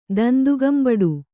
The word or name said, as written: ದಂದುಗಂಬಡು